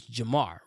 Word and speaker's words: Jarmar: My name is Jamar, right? But it doesn't, it's not spelled Jamar, it's spelled Jarmar, right? So it's J-A-R-M-A-R.